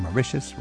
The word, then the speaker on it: Mauritius: Learning more about this island getaway, it just seems right that Mauritius rhymes with delicious.